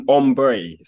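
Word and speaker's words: Ambré: Are you familiar with an artist called Amber or Ambre? – An artist? – Yeah, she's a singer. – Amber Ray, right? – Or Ombre. So there's like an accent on the E.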